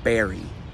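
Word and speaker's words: Barre: Okay, this one's real short. Should be really straightforward, right? Wrong. This is pronounced Barry.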